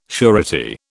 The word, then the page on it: surety: British English